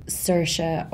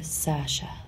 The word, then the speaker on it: Saoirse: It's Saoirse, but it's easier for Americans and English people to say Saoirse or Sasha. – What was the difference? – Saoirse is how Irish people say it.